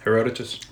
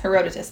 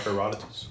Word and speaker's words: Herodotus: Um, and then in 425 BC, Greek historian Herodotus, Herod, mm, Herodotus, how do I say this? – Herodotus. – Yes, Herodotus. Thank you. – Or Herodotus.